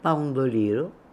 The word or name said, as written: Poundoliro